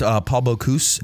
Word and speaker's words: Bocuse: It was created in 1987 by Paul Bocuse. Am I saying his name right? – It's Paul Bocuse.